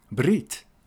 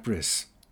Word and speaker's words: Brit: From then on, it would be Shabbat and not Shabbos, Brit and not Bris. 40 years after doctors told him that he would not have a year to live, he continued to fight for his cause.